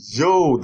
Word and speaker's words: Zhou: In China, we have the most powerful king of the Zhou dynasty. Our Chinese listeners have complained. It's not pronounced the Su dynasty, but the Zhou dynasty. – Zhou? Okay. – Just like average Zhou.